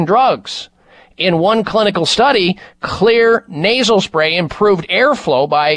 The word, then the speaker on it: Xlear: Xlear nasal spray with xylitol. Xlear is spelled with an X. X is for xylitol. X L E A R.